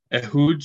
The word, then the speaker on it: Ehud: Next one was Ehud or Ehud, how do you pronounce that? – Ehud, so he was the descendant of Benjamin, which you can, I guess, you hear more about him in First Chronicles, I believe 7.